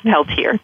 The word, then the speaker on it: Peltier: Yeah, we don't do the French way. We do the regular, the American way, I guess, Peltier. Just two syllables, Peltier.